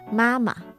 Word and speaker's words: 妈妈: I'm just saying the proper pronunciation is Mama.